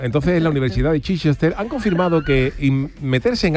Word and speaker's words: Chichester: Nos hace gracia el nombre de Chichester, pero el nombre ya es de coña. – Esto, Jesús, como el... Eso sería, se pronunciará Chichester o algo así, ¿no? – Chichester, claro.